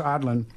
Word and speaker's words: Aadland: Remember this young man, spells his last name A-A-D-L-A-N-D, pronounced Adland, and he's challenging Michael Bennett.